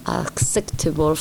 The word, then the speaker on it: acceptable: Aceptable es lo que nosotros pensamos porque al saber que la palabra es aceptable, nos recuerda que table en inglés es como se escribe mesa, pero se dice table. Y en este caso este able se va a decir able sin la I. Entonces es acceptable. Acceptable.